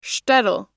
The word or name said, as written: shtetl